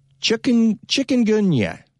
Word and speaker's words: Chikungunya: Chikungunya. So I think that's something like that. That's how it's pronounced. And it has nothing to do with chickens because it's spelled C-H-I-K-U-N-G-U-N-Y-A.